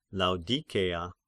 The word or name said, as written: Laodikeia